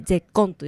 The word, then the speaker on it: 舌根: 舌根、下の根っこって書いて舌根と読むんだけど、それが全てこの喉の奥の声を出しづらくするっていうね、嫌な力が入っちゃう。どういうことかっていうと、舌根